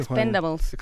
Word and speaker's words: Expendables: De Expended, ¿cómo se pronuncia, Expendables.